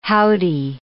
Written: howdy

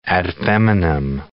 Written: ad feminam

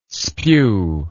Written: spew

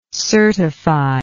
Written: certified

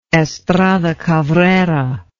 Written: Estrada Cabrera

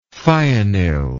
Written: thionyl

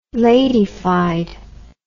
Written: ladyfied

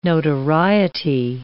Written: notoriety

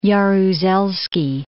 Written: Jaruzelski

